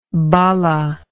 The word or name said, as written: balla